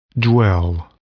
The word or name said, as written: dwell